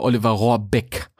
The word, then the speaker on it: Rohrbeck: Also Leute, ich weiß, wie der, äh, wie der Oliver Rohrbeck heißt. Und dass der Beck heißt und nicht Berg.